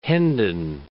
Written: Hendon

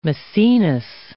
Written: Maecenas

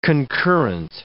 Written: concurrent

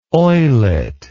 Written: oillet

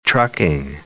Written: trucking

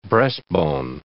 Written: breastbone

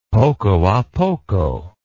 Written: poco a poco

